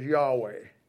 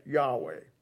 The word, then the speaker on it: Yahweh: The original name from God is that what we pronounce as Yahweh. And Yahweh it's hard to know exactly how to pronounce it because there's no consonants and there's no vowels. That's the closest we can get, Yahweh.